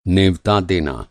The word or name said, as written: नेवता